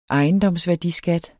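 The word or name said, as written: ejendomsværdiskat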